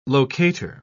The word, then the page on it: locator: British English